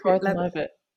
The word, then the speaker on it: Gordon-Levitt: And Gordon-Levitt Hewitt, how do you pronounce his name? – Gordon-Levitt. Who's Levitt Hewitt? – Yeah, I don't know.